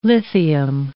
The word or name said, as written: lithium